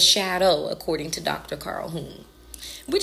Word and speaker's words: Jung: So his name is pronounced Carl Jung.